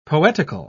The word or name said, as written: poetical